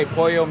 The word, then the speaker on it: pollo: You could just say pollo means chicken. – Pollo, but you're not pronouncing, you gotta say pollo.